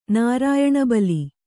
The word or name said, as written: ನಾರಾಯಣಬಲಿ